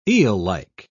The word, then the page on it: eellike: British English